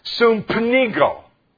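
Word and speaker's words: sumpenigo (συμπνίγω): The word choke in the Greek is, and here's how it's pronounced, sumpenigo.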